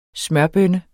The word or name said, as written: smørbønne